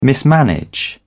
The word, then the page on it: mismanage: British English